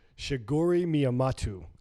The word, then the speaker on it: Shigeru Miyamoto: Shigeru Miyamoto, I believe is the pronunciation. – I think you got that. I think you nailed that.